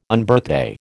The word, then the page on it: Unbirthday: American English